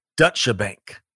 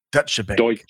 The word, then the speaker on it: Deutsche Bank: Fascinating business study, and to project, and we'll see if, we pronounce the T, Deutsche Bank. – Deutsche Bank.